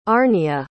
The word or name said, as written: Arnia